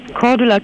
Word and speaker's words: Cordula: Cordula Kienef.